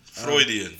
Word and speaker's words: Freudian: Yeah, and then Daniel Caesar's, I think you pronounce it Freudian or Fruidian. – Freudian. – Freudian. There we go. – Is it F-R-E-U-D? – Yeah.